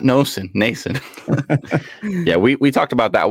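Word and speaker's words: Stefan Noesen: We ended up getting a fourth round pick for retaining some salary on Foligno and giving up Stefan Nason. Like how I pronounced that name there? – That was perfect.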